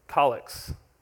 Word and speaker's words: collect: We're not Anglicans, but there are these short little collects or collects. One of these other, Luke, I'm sure you know how to pronounce it. It is collect.